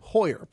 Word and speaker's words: Heuer: Tag Heuer. Is that am I even pronouncing that right? – Heuer. – Pardon me. Heuer.